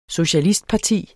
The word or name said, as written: socialistparti